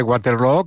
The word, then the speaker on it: Waterloo: Pues no lo es, desde luego, pero ha hecho mucho daño en la pronunciación de Waterloo, que se pronuncia igual que Feijóo.